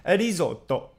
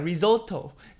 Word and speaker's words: risotto: 上礼拜我们讲了披萨，那这礼拜我们就来讲另外一个意大利的标志美食，也就是我们的意大利炖饭 risotto。哈，那当然我们英文讲 risotto，但是呢，意大利文的发音是 risotto。